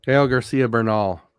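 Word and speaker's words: Gael García Bernal: Gael Garcia Burr. Oh my God. I just had it too because I wanted to say it right. Bozo. Come on. Where's it at? Gael Garcia Bernal.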